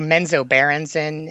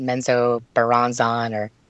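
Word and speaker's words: Menzoberranzan: And I say Menzo Berenzen, and other people pronounce it Menzo Baranzon. So, yeah, there's many different ways to pronounce it.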